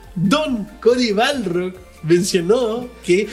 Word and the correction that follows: Barlog: Don Cody Balrog. – Barlog. – Sí, siempre lo digo mal. – ¿Y sabes qué? – Barlog.